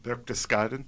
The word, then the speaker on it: Berchtesgaden: Berchtesgaden.